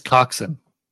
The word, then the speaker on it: coxswain: It's coxswain. – What is? – That's how you pronounce it. Coxswain. The W is like silent, apparently.